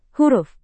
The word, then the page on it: huruf: Indonesian